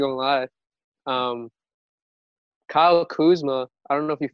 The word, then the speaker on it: Kuzma: Kuzma.